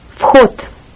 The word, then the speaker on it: вход: The word for entrance in Russian is вход. – Вход. – Вход. The entrance. Notice that the Russian he looks like an English X, but is pronounced more like a very rough H.